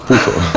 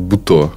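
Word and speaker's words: путо (舞踏): Путо. Да, японцы говорят путо. Почему-то здесь адаптировалось как буто.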